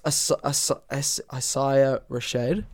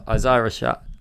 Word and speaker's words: Isaiah Rashad: It's Isaiah Rashad. – Oh, Isaiah Rashad. – Sure. Yeah. – It's Isaiah, right? – Isaiah Rashad.